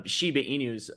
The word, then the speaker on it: Shiba Inu: Speaking of Shiba Inus, is that how you say Inus or Shiba Inu? – Yeah, okay. I think so.